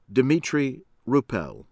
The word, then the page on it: Dimitrij Rupel: American English